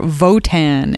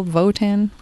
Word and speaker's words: Wotan: I think the other time that I watched it, I wasn't listening closely enough to recognize that they were pronouncing it, you know, German style Votan.